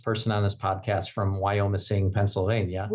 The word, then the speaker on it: Wyomissing: Wyomissing was a community that grew up around. – I know I wasn't pronouncing it right, and you would get me.